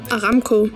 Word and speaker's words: Aramco: Aramco.